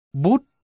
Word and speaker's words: Buddha: Chữ Buddha hoặc Buddha, người giác ngộ, chỉ Đức Phật, đúng ra chỉ có một âm, bút, gần như âm bụt trong tiếng Việt.